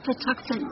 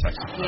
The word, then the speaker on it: Patuxent: I can't pronounce that. – That's Patuxent Records. – Patuxent.